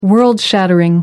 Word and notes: world-shattering: American English

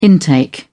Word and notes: intake: British English

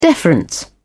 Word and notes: deference: British English

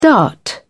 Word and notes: dart: British English

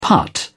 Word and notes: putt: British English

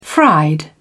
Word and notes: fried: British English

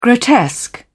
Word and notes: grotesque: British English

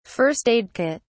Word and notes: first-aid kit: British English

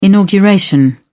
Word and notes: inauguration: British English

